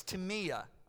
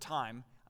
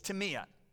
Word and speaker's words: timia: It's timia, all right? We would pronounce it time. The spelling's going to be up there. It looks like time. It's timia.